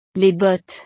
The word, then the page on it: les bottes: frances